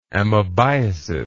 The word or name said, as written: amoebiasis